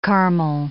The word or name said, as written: caramel